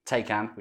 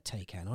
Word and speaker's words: Taycan: You're not supposed to call it a Taycan, are you? It's like a Taycan or something. You're supposed to... So it's not quite say it properly. It's supposed to get lost in your throat, isn't it?